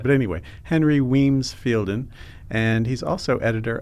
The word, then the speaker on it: Wemyss: the Civil War Letters of Henry Wemyss. – It's Weems. – It's Weems, okay. – That's why the British pronounce Weems.